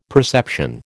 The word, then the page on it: perception: American English